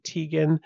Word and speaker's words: Tegan: I would have pronounced it Tegan, but I saw her uncle on like a press conference with the RCMP and he pronounced it Tegan. And I'm pretty sure uncles don't usually mispronounce their nieces' names.